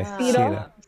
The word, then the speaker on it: Sehra: So it's actually pronounced SEHRA. – SEHRA. Okay, okay. – SEHRA. Cedar.